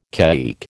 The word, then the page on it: caique: American English